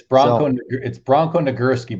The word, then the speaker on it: Nagurski: It's Bronco Nagurski.